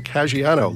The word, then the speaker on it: Caggiano: My guest this week is Lauren Caggiano.